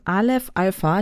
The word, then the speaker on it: Aleph Alpha: Und du bist ja von Aleph Alpha.